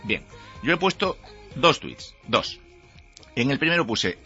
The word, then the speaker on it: tweets: y voy a dar mis dos últimos tweets. Se dice así, ¿no? ¿Es correcto? Tweets. – Sí, está bien.